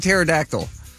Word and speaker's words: pterodactyl: No, it's a pterodactyl. So... – I know, what's the silent letter? – Here's a helpful hint. You can't hear them when they go to the bathroom because the P is silent.